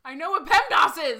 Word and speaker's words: PEMDAS: It's ass. A-S. – I know, but it's a long A, not a short A. PEMDAS.